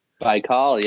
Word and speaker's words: Baikal: But those guys went on like a huge road trip, and one of the destinations was Lake Baikal. Is that how you say it? – Baikal, yeah.